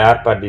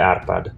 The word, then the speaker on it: Arpad: You know and uh, Gere Arpad is uh, it, so this is how it's pronounced in Hungarian Gere Arpad.